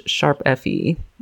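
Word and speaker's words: ♯FE: We're going to talk about Tokyo Mirage Sharp FE. That's how you pronounce it. This is what I've heard, that this is how you pronounce it. – And I, too, have been saying hashtag this whole time. So if you are like me, do not feel sad or guilty. – No, it's a sharp.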